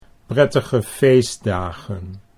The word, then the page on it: prettige feestdagen: Dutch